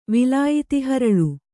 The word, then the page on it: ವಿಲಾಯಿತಿಹರಳು: Kannada